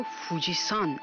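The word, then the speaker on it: فوجیسان (富士山): ژاپنی ها این کوه رو فوجیسان تلفظ می کنند. این سان پسوند احترام که به نام افراد اضافه میشه نیست، بلکه نحوه خانش کانجی کوه.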